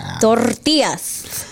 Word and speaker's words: tortillas: Say it right if you're going to say in a cooking show. Tortillas. – Tortillas. – Las tortillas. – Tortillas. – Yeah, say just like that.